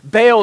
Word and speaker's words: Baal: I'm pretty sure, and there's debate over this, but I'm pretty sure Baal is actually supposed to be pronounced Baal. Here's the deal, though that's not easy to say.